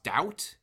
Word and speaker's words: Douthat: They have found this, like, both through David French, who and also Ross Doubt. Is that how you pronounce his last name? The New York Times opinion columnist? – I've never pronounced it that way, but yes. – Well, it's important that we reference... that's how I've always said it, but maybe it is Doubt. Like, I don't know. – I want to say it's Doubt because I want to say that's what they said. I could be wrong, but I want to say they pronounced it Doubt. Anyway, if I'm getting it wrong, but D-O-U-T-H-A-T.